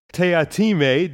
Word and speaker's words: Teatime: Mr. Tea Time, or perhaps Mr. Teatime, depending how you pronounce that, played by Mark Warren, is the assassin sent to kill the Hogsfather. And also a distinctive figure with a black glass eye and his other eye being almost all white. So quite striking, and this very strange character in here. – Yes, always insisting that it's pronounced Teatime to anybody who tries to call him Tea Time.